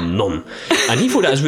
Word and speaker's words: non: I always thought it was non. – Yeah. – You don't pronounce the second N.